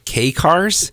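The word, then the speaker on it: kei cars: Colorado passes law to officially allow, I believe it's pronounced K-Cars, K-E-I, starting in 2027.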